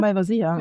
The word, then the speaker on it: Malvasia: Man sagt Malvasia, ne? – Malvasia. – Ja. – Also die Betonung. Also wahrscheinlich sagt man eher auf Madeira Malvasia. Also ähnlich. Man sagt ja auch nicht Madeira, sondern Madeira.